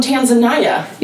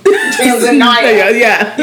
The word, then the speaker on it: Tanzania: She's like, it's pronounced, someone was trying to correct me and say it was Tanzania. And I was like, if you're not from there, it's just like Americans call Rihanna, Rihanna.